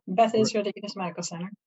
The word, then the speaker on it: Beth Israel Deaconess Medical Center: Yeah, Beth Israel Deaconess Medical Center.